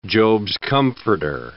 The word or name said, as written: Job's comforter